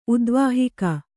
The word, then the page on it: ಉದ್ವಾಹಿಕ: udvāhika